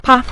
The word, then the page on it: pa: pa5